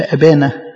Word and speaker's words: أبا: عشان كده حتى التعبير اللي استخدمه السيد المسيح في أبانا، يعني في اللغة الآرامية اللي كان بيقولها السيد المسيح، يعني تتنطق أبا.